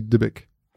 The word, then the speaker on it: Dybbuk: Ich glaube tatsächlich, es wird Dibbuk ausgesprochen. Es schreibt sich halt D-Y-B-B-U-K.